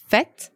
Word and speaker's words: fête: Right, you will find it in the words fête, which means party, and fête is spelled F, E accent circonflexe, T-E.